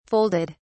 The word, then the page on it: folded: British English